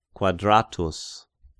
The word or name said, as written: Quadratus